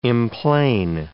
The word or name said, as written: emplane